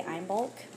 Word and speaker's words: Imbolc: It's spelled I-M-B-O-L-C, so I'm going to say Imbolc.